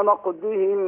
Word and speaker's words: نقضهم: فبما نقضهم ميثاقهم. – لا نقضهم كده انت نطقت الضاد دال. نقضهم لو نقضهم ضهم. – فبما نقضهم ميثاقهم وكفرهم بآيات الله وقتلهم الأنبياء بغير حق وقولهم قلوبنا غلف بل طبع الله عليها بكفرهم فلا يؤمنون إلا قليلا.